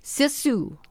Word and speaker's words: sisu: And that's one thing I also have learned from the Scandinavian culture is, and you pronounce it S-I-S-U. I want to hear the way you pronounce it. – The Suomi Hall? – No, oh. S-I-S-U. – Oh, sisu. – Sisu.